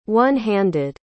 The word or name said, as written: one-handed